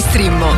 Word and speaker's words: Streammo: Entra adesso nel mondo di Strimmo. Vai su strimmo.it, si scrive Streammo o scarica l'app.